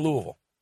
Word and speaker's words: Louisville: You're in Louisville. – Right. – How did I do? Did I say that right? – Yeah, you did. I was hoping you weren't going to say Louisville, and you didn't.